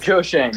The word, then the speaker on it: Schoen: Was it Joe Shane? Is the pronunciation correct? – Joe Shane. Yeah.